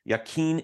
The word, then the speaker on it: Yaqeen: And correct me if I don't pronounce this right. Yakin Institute. Is that how you say it? – Right.